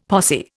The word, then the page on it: posse: Indian